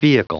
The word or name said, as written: vehicle